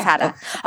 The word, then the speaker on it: Ofada: Wait, so, and just tell me, so the difference between Ofada, am I saying that right? – Yeah. – Ofada. Okay, Ofada rice and jollof.